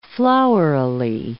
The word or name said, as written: flowerily